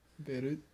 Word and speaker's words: Beirut: No, you pronounce the T. – Beirut.